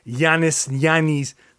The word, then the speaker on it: Yannis: So, and yes, by the way, Yannis, like that's my name, my first name, and Yannis Antetokounmpo. We have the same first name. But that's the correct pronunciation.